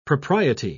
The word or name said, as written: propriety